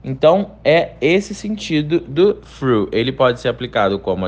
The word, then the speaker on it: through: A pronúncia do through dá um pouco mais de trabalho do que os anteriores, porque para você pronunciar o TH, você coloca a sua língua entre os dentes e deixa o ar sair de uma maneira mais leve. Through, through.